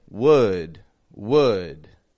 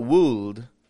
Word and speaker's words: would: It can be a W-O-O-D or W-O-U-L-D. Both come out sounding the same. Would. Would. And now a side note on that W-O-U-L-D, that L is silent. It doesn't get pronounced at all. It's not wooled, like W-O-U-L-D, wooled, but would, would.